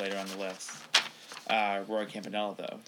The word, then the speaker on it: Campanella: Is it Caminola? Roy Caminola? – Campanella.